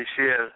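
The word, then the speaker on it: Michel: Am I saying that wrong? Because that's a dude. Michelle. Anyway. – Michelle. – right. Make it sound French.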